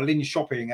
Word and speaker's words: Linköping: So it was always going to be a very tricky game, especially when you remember that Linköping, or Linköping, as I think they're pronounced in Sweden, they, of course, are midway through their season already.